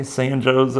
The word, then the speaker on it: San Jose: It's like, you need to just know that if it's in Illinois, it's pronounced San Joe's. So, okay, San Joe's, Illinois.